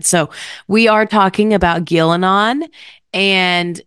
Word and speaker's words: Ghilan'nain: So I pronounce that as Ghilan'nain.